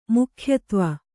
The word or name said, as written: ಮುಖ್ಯತ್ವ